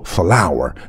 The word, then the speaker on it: flour: 比如说花朵叫做 flower，面粉也读作 flour，只不过拼写不同。